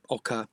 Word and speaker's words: oca: And that's spelled O-C-A for all the listeners. – I would love to talk about ochre, as I sometimes call it as well.